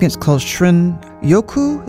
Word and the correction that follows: Shinrin-yoku: Is that how you pronounce it? The Japanese term for wood air bathing. – Shinrin-yoku. – Shinrin-yoku.